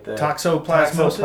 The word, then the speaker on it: toxoplasmosis: Am I saying it right? – Toxoplasmosis. – Toxoplasmosis. Yeah.